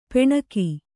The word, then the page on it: ಪೂಣಿಗ: pūṇiga